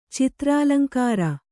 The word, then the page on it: ಚಿತ್ರಾಲಂಕಾರ: kannada